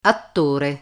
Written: attore

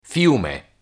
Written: fiume

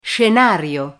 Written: scenario

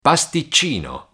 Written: pasticcino

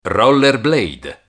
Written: rollerblade

